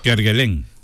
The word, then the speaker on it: Kerguelen: Kerguelen. – Ah, en Kerguelen.